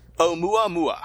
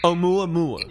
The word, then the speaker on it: Oumuamua: I think it's Oumuamua. – Oumuamua.